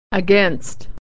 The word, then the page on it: against: e